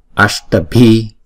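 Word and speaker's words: aṣṭābhi: Ashtabhi, ashtabhi, chữ S và chữ T chúng ta đọc cong lưỡi lại và búng ra. Sh, t, ashtabhi, bhi, chữ B và chữ H đọc là b. Ashtabhi, maha, daruna, maha, daruna, maha, daruna.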